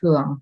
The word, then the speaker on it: Huong: So my real name is Huong, which is very hard if you're not Vietnamese. To pronounce it.